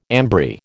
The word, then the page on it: ambry: American English